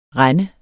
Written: rende

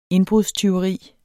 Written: INDBRUDSTYVERIS

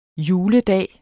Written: juledag

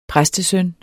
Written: præstesøn

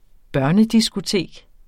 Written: børnediskotek

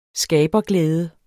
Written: skaberglæde

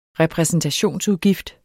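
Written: repræsentationsudgift

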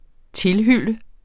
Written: tilhylle

